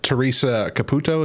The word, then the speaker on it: Teresa Caputo: Because Teresa Caputo, is that how you say her name? – Yep.